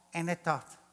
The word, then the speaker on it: Anathoth: In a town called Anathoth. That's how you pronounce it. It's spelled A-N-A-T-H-O-T-H. Anathoth.